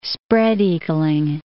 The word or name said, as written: spread-eagling